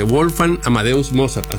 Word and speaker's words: Wolfgang Amadeus Mozart: Wolfgang Amadeus Mozart, así es, ¿no? – Bien, así es.